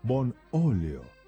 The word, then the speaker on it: Bonolio: Aceite Bonolio, Bonolio, con acento en la O.